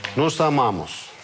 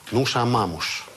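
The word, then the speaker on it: nós amamos: И здесь сразу делаю оговорку, что в Португалии и в некоторых районах Бразилии читается это «нуша мамуш».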